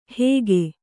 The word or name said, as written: ಹೇಗೆ